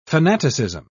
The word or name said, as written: fanaticism